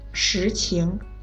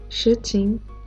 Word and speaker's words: 实情: 事情的“ 事” 是四声，情是轻声，事情；实情的“ 实” 是二声，情也是二声，实情。大家说的时候一定要注意声调。 – 事情、实情，对吧，猪猪？ – 对，非常好。